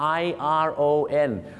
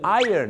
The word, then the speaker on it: iron: I R O N ก็คือ iron นั่นเองครับแต่ไม่ต้องถึงขนาดเหยินเหมือนที่ผมออกเสียงนะ